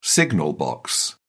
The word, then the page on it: signal box: British English